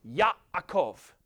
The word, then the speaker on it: Yaakov: But it's Yaakov. There's a little catch in your way. Yaakov there.